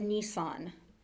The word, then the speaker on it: Nisan: In the Jewish calendar, it is the 10th day of the Hebrew month of Nisan. It's pronounced just like the car.